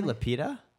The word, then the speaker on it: Lupita: Oh, wait, did you say Lupita? – Yes.